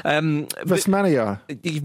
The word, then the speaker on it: Vestmannaeyjar: Vestmannaeyjar.